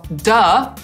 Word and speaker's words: duh: I wymawia się to jako duh, a piszemy duch przez samo H.